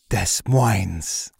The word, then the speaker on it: Des Moines: S is silent. Both S's, right? – There's actually 14 S's in Des Moines. – Because it should be Des Moines. – Des Moines.